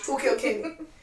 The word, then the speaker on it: Who Killed Kenny: How do you say it? – Who killed Kenny? Like, from South Park.